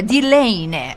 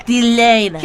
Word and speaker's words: длинная: تلفظش اینه. دیلینه. – دیلینه. – که نوشته میشه دی. – دی. دی. دی دی دی صندلی، شبیه کلمه صندلی.